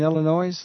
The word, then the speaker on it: Illinois: Joan and Ruth and Jenny was on a retreat in Illinois last month where they met Father Mel, so... – And not from Illinois because we don't pronounce the S at the end of that word.